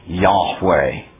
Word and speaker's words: Yahweh: and that they are convinced that the proper pronunciation of the Tetragrammaton is Yahweh.